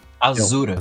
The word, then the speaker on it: Asura: Aí a gente tá enfrentando um tal de Asura, Ashura, como é que fala isso? Azura.